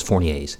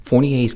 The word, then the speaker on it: Fournier's: The first one is Fournier's gangrene. The R is silent, don't worry. It's French.